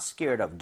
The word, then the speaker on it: scared of: So she's not scared of dogs. – Yeah. – Very nice. – Mm-hmm. – 근데 이게 빠르게 읽잖아요. – 네. – 그럼 이제 연음 현상이 벌어지면서요. 여기가 리을 비슷하게 발음이 될 거예요.